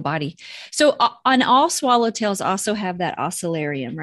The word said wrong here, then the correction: osmeterium: Is that what it's the osmeterium? – Osmeterium.